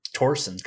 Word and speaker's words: Thorsen: Sven Ole Thorsen. I believe so. He's Danish, and I think the Danish pronunciation of that last name is Torsen's kind of like Tor, not yeah.